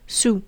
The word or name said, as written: su